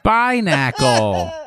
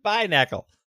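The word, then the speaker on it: Binacle: Binnacle. – It's Binnacle. – Oh, yeah. I haven't heard that one said out loud. But I believe there's a whole... – It rhymes with tabernacle.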